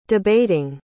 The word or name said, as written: debating